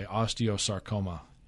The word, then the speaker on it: osteosarcoma: And I think you did say that right, by the way, osteosarcoma. Is how I would pronounce it anyway.